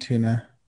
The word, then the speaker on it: Antuna: But this year, the Nats protected, what did we say was Adon and Yazel Antuna, I think is how you say Antuna. – It. – Antuna.